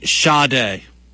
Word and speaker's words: Sade: Yeah, so apparently it is Sade. Sade. Pronounced S-A-D, or spelled S-A-D-E. Sade. Yes, thank you. I don't know where I got that from. I don't know where I heard that from, but that's what I heard. But it's Sade.